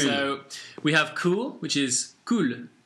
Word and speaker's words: cul: And then we have the French word for arse, which is spelt C-U-L, which is pronounced cool. – Cool. – If you notice, there's slightly less stress on the L at the end, so it's cool.